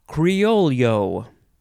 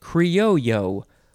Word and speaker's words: Criollo: Okay, so the first word in this episode is Criollo. Criollo, or you can say it Criollo without the L sound.